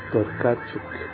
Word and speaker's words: Tokarczuk: Por exemplo, a Olga se pronuncia Tokarczuk.